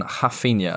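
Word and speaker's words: Raphinha: Jesus and Rafinha, as I'm reliably informed it's pronounced.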